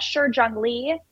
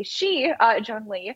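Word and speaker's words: Shi Zhengli: Right to Know Foundation show that Shi Jiangli, thank you for helping me with my pronunciation. It was you, Jack, who corrected me. I used to say Shi Jiangli.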